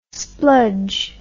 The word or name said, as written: spludge